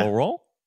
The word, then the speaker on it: Laurent: He's there with, Palmer's there with, how do you say his name? Laurent? – Yeah. – Laurent? – Crushed it.